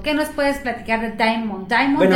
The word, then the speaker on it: Damon: Es, Damon, pero es Damon, así como se escribe.